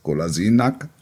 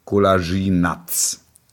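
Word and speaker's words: Kolašinac: Die Aussprache des Nachnamens des Spielers Golasinak. Er heißt jetzt nicht mehr Nack, sondern Natsch. Und jetzt, Achtung, dringende Mitteilung an alle Redaktionen. Spieler heißt jetzt Golasinats.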